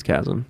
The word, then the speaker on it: chasm: No, I think it's chasm, like chaos.